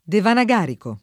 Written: devanagarico

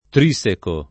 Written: trisecare

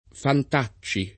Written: Fantacci

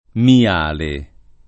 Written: Miale

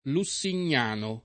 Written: lussignano

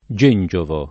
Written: gengiovo

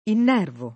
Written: innervare

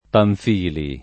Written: Pamphili

